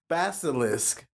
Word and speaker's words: basilisk: Basilisk? – Yes. – Or a Leviathan? – Because they're two different things. – What the basilisk is. Balicus? Basilisk. – There you go. – The basilisk.